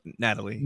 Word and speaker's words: Natalie: I think it's pronounced Natalie.